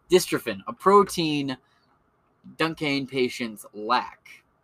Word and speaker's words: Duchenne: Duchenne.